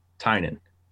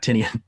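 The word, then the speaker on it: Tynion: The correct pronunciation is Tynan, not Tinian.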